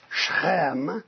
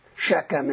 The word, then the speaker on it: Shechem: Does the story of Dinah and Shechem, in Hebrew it's Shechem is the way it's pronounced, Shechem is the kind of the anglicized form, talk about how the Israelites are a small nation.